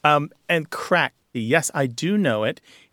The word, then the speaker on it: craic: This word crack, spelled C-R-A-I-C, but pronounced crack, is common parlance in Ireland.